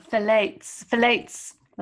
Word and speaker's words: phthalates: So phthalates.